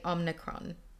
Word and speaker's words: Omicron: How to say Omicron.